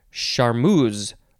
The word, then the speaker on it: charmeuse: Next is charmeuse, or charmeuse, or charmeuse, or charmeurs. So many ways to say this. It is spelled C-H-A-R-M-E-U-S-E. I'll say charmeuse.